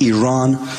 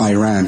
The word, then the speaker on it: Iran: You're pronouncing our country's name wrong. Our country is named Iran, not Iran.